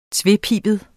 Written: tvepibet

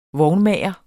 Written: vognmager

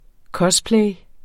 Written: cosplay

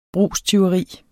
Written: brugstyveri

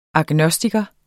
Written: agnostiker

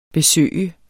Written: besøge

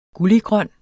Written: gulliggrøn

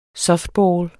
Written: softball

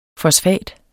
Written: fosfat

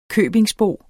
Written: købingsbo

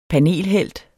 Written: panelhelt

